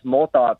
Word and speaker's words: Molthoff: It's Molthoff. It's German, actually.